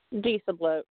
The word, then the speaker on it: Disablot: Disabloat. Yeah, basically. – Spell that. – D-I-S-A-B-L-O-T. Disabloat is it, and there's also Charming of the Plow.